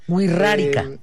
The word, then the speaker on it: Wixárika: Así es, básicamente en la cultura la X muchas veces es utilizada como R, o bueno, pronunciada como doble R incluso. – Wixárika.